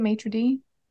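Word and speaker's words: maître d': There's a report from a waitress working late one night, and as she was walking towards the maitre d' stand, is that how you say it, maitre d'? – Mm-hmm.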